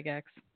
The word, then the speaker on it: Gygax: So it was first designed by the legendary Gary Gygax. Did I say that right, Zach? – Yes. – Gygax? – That is absolutely correct.